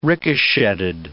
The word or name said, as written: ricochetted